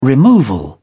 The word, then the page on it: removal: British English